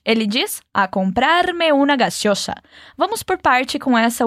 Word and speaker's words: gaseosa: Gaseosa. E nessa palavra, a gente tem duas vezes o sonzinho S. Aquele som do refri bem gelado abrindo. Gaseosa. É só lembrar disso para ficar mais fácil de pronunciar.